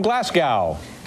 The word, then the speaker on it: Glasgow: Our next guests are a band from Glasgow. I pronounce that right? – Yeah. Absolutely.